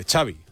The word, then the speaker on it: Xavi: Nada, tiene gracia porque lo primero que le pregunta es cómo se pronuncia Xavi Hernández y dice Xavi, like, como chocolate, Xavi, chocolate, Xavi Hernández.